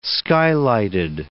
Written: skylighted

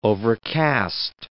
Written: overcast

